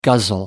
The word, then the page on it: guzzle: American English